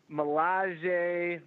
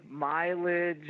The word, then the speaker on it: Millage: How do you say your last name? – Millage, Mileage, Millage, whatever.